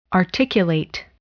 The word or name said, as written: articulate